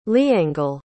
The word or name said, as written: leangle